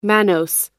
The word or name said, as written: mannose